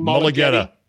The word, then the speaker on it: Mulugheta: Mulugheta.